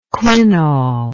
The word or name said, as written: quinol